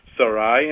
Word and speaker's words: Sarai: His name is Abram, in Hebrew, Avram, and her name is Sarai. That's probably, you know, that's true to the way it's pronounced in Hebrew also, Sarai.